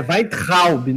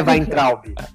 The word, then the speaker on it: Weintraub: Não, você tá falando errado. É Weintraub, não é Weintraub.